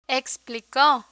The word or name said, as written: explico